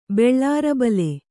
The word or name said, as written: ಬೆಳ್ಳಾರಬಲೆ